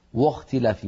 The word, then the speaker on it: واختلاف: واختلاف، لابد من أن ننطق الواو مرققة، فلا نقول وخ واختلاف، بل نرقق الواو ونفخم الخاء هكذا واختلاف.